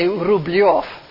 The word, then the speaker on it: Rublev: Well, Russian has this nasty habit of changing yef into yof. So Rublev becomes Rublev if it's under the accent.